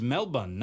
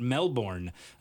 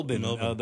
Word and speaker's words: Melbourne: After practice, I asked Kyra Webb how to say that word, since she's from there, and she says it's Melbourne, not Melbourne. Melbourne.